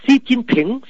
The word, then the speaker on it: Xi Jinping: Chữ tập là đọc số hỏi tiếng Việt Nam Xí, còn chữ cận đọc dấu sắc tiếng Việt Nam chín, nhà mà chín nhớ là chín tiếng Nam á nha, chín yến thì yến tiếng Bắc mà là CH tiếng Nam, chờ yến chín. Còn chữ bình đọc là thỉnh, Xi Jinping, Xi Jinping.